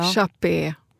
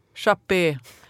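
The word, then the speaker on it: Chappe: Eller Chapp, hur uttalar man det där? – Hur ska det vara på finska? – Ja. Chappé. Chappé. Här i Ekenäs, Finland.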